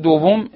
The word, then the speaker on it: دوم: در مورد دوم عنایت داشته باشید که تلفظ صحیح این کلمات بدون تشدیده. دوم و سوم.